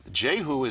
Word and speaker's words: Jehu: I don't think J-E-H-U, and right. In Hebrew it would be Yehu. It would be Yehu. – Okay. – It was like Yahoo. No. So what happens is that Jehu is not a royal person.